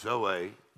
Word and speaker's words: zoe: We know the Greek word there is the word Z-O-E, which we pronounce Zoe.